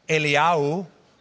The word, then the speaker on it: Eliyahu: In Hebrew, it would be very easy to tell the difference. The first prophet is pronounced Eliyahu. Eliyahu. The second one, Elisha.